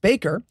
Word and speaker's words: Backer: We also had an email from Alex asking, now that you've made your way through the backer bucket, which is pronounced baker, thank you for suffering for our sins.